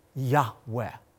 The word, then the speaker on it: Yahweh: Now, the Lord, Yud-Heh-Vav-Heh, oftentimes pronounced very, with such reverence, a breathy Yahweh.